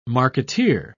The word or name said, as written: marketeer